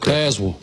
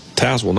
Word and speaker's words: Tazewell: Tazewell. – Tazewell, not Tazewell, which is exactly how I would have said it.